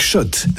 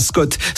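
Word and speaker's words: Schott: Jouer et gagner sur RMC avec Shot, la marque iconique new-yorkaise depuis 1913. Oui, Shot et pas Scott. Ça fait des années qu'on prononce mal la marque.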